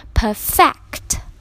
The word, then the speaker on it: perfect: perfect，没错，就是 perfect，完美的、优秀的意思。但是呢，这个单词在动词的形式下呢，则是要读成 perfect， perfect，表示使什么更加的完善，使什么变得更加完美的意思。